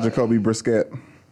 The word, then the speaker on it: Jacoby Brissett: Shouts out, Jacoby Brissett. – Sure. Yeah. – That's how you say his name.